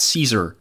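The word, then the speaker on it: Szczur: Matt Caesar. That one's great. Yeah. It's S-C-Z-C-Z-U-R, I think. And somehow it's pronounced just like Julius Caesar.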